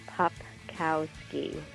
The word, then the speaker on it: Popkowski: Well, it's funny because it's very phonetic. It's Popkowski.